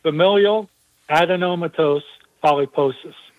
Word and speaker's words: familial adenomatous polyposis: It's pronounced, at least I pronounce it, familial adenomatous polyposis.